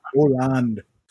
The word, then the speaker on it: Åland: Åland.